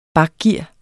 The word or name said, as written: bakgear